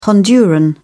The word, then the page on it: honduran: British English